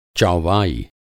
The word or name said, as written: चौवाई